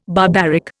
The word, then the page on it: barbaric: Indian